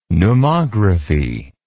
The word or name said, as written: pneumography